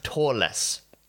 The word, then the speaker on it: Törless: Törless. And there is an umlaut. I'm pronouncing it weird because there's an umlaut above the O.